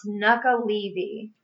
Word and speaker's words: Nuckelavee: So it's pronounced Nuckelavee.